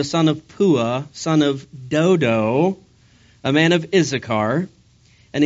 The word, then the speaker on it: Dodo: It's bad enough telling people you're the son of Pua, even worse to tell them that your grandfather's name is Dudu. No, actually, it's pronounced Dodo, and I apologize, that's the second straight message where I said Dudu.